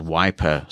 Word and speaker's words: Wipr: I think the one I'm currently using is called Wiper, spelled without an E, so W-I-P-R.